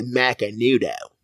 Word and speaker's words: Macanudo: You got to say it like that. Macanudo.